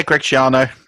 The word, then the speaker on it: Schiano: Yeah, Greg Schiano.